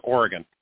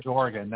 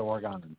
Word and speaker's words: Oregon: It is Oregon, not Oregon. – Dang it, Oregon.